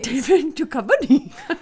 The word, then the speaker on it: Duchovny: David Duchovny. But you just added an extra syllable in there. – I can't talk these days. – David Duchovny.